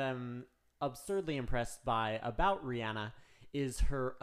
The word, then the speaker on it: Rihanna: Going to be totally honest, I have always pronounced her name Rihanna, but I've heard in several interviews she pronounces her own name Rihanna.